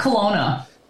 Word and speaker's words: Kelowna: Oh, Kelowna. – Kelowna.